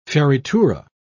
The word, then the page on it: fioritura: British English